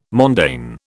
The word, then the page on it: mondaine: British English